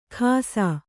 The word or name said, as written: ಖಾಸಾ